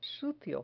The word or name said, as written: sucio